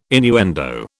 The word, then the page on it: innuendo: British English